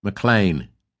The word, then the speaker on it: McLean: He pronounced it the Scottish way. "McLean."